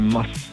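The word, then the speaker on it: Maß: Die Betonung ist auf dem S, also eine Mass.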